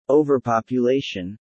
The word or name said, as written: overpopulation